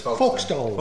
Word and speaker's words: Folkestone: Folkestone.